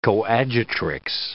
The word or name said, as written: coadjutrix